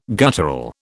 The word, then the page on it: guttural: British English